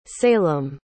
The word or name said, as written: salem